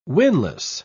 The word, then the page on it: winless: British English